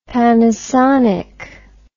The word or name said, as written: Panasonic